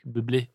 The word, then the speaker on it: Bublé: Det er fransk. Bublé.